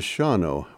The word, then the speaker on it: Shawano: And then there is Shawano, where the second A is silent.